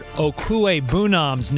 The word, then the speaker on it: Okwuegbunam's: You know, I'm the only broadcaster who can pronounce Denver Broncos tight end Albert Okwuegbunam's name correctly. – Yes, everybody else, they fumble it somewhere between the O and the Nom.